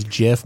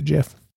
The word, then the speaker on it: GIF: It's a soft G. It's a GIF.